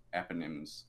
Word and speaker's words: eponym: sons, Manasseh and Ephraim, were adopted by Jacob and they became the tribal eponyms. Eponyms? How do you pronounce that? – Eponym.